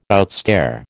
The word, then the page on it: outstare: American English